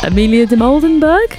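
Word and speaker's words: Amelia Dimoldenberg: I saw a video of now, I'm going to get her last name wrong. Amelia de Moldenberg? Correct, Joe's nodding.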